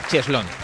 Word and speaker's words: cheslón: Eso sí, escrita cheslón como suena. C-H-E-S-L-O-N, cheslón. – Con acento en la O.